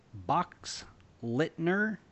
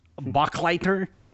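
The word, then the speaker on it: Boxleitner: Box Lightner. – Got to ask the Babylon 5 fans. Yeah. They'll tell you how to say it. – Bachleitner? – No, you had it.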